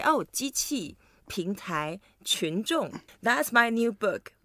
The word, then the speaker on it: 群众: 群众. – 群众. – 群众. And it's so heavy.